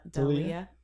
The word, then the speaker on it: D'Elia: Yeah, um, like I always say his name Del- D'Elia.